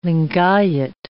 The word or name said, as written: Lingayat